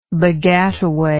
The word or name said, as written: baggataway